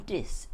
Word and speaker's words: Diss: My father wrote to his uncle in Diss in Norfolk. That's spelled D-I-S-S.